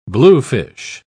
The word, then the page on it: bluefish: British English